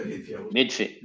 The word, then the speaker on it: Bitfy: que es bueno, tu faceta como inversor, como impulsor de, proyectos de una startup como, a ver si lo digo bien, Bitfy, se dice BitFi, ¿verdad? – Pues se dice BitFi.